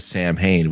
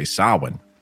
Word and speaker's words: Samhain: And, you know, he says Samhain, which basically is the wrong way of saying it. You're supposed to say Samhain. – Samhain, yeah. – He's saying it wrong. But you well, know, so did Loomis. Loomis said it. It's a Celtic word.